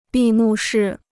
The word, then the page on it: 闭幕式: bì mù shì